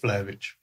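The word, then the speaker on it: Vlahović: That guy, is he called Vlahovic? – Yeah. – How do you pronounce it? – Vlahovic.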